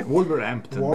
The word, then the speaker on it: Wolverhampton: 2-2 fa Newcastle e Wolverhampton, se avete detto bene. – Wolverhampton. – Bello, avete detto bene voi.